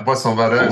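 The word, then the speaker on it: Boisson-Vadot: Although I have to say that I just got a slight preference for the Boisson Vado. Is that how you say it? – So, yeah, Boisson Vado. So, played 2-1-2 for Boisson Vado.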